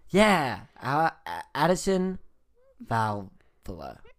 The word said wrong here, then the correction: Vavola: Vavola. – Vavola. – Yeah. – Said it right.